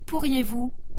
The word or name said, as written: pourriez-vous